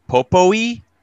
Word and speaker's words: Popoi: So and the sprite's name is Popoi, P-O-P-O-I.